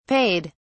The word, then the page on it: payed: British English